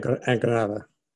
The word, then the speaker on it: Granada: Okay, so Murcia, Almeria, and Granada Okay, forgive my Spanish, pronunciation. You can correct me. – So Murcia. – Thank you. – Almeria. – Yeah. – And Granada.